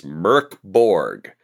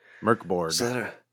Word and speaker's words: MÖRK BORG: And it says it's Mörkborg. – Mörkborg.